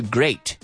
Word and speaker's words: grate: Rayar, to grate. Grate, se escribe grate, G-R-A-T-E. But it's pronounced like great. Echar. Con un líquido. To pour. P-O-U-R.